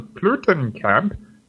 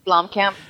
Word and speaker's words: Blomkamp: Next stop, Elysium, from the District 9 writer and director Neil Blomkamp, and starring Matt Damon. – What? How do you pronounce that? – Blomkamp. – It's Blomkamp.